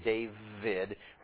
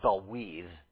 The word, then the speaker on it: David: A dalet, which is like our D, a waw, which is like our W, but in modern Hebrew a V, that's why you call him David, rather than Dawid, which is how you would pronounce it in ancient Hebrew, and then a dalet again. So D-W-D, or D-V-D.